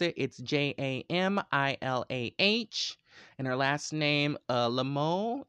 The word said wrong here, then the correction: Lemieux: it's some kind of French, I believe. Lemieux.